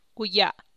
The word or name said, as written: guya’